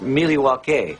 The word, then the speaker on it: Milwaukee: Actually, it's pronounced Miliwake, which is Algonquin for the good land.